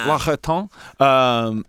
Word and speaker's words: L'Occitane: I've stayed at this hotel twice in Santa Barbara that has L'Octane. Is that how you say it? – L'Occitane. – L'Occitane.